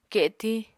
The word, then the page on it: ketiñ: Apatani